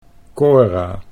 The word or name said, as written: Cora